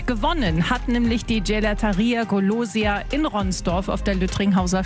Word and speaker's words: Golosia: Best of Wuppertal und die beste Eisdiele der Stadt ist die Gelateria Golosia. So spricht man es richtig aus. Golosia sagen die Wuppertaler und die Ronsdorfer.